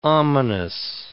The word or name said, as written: ominous